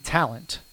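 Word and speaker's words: Tallent: Sam Talent, another Sam. This guy I don't know. Sam Talent, it's spelled like the word talent with an extra L, T-A-L-L-E-N-T.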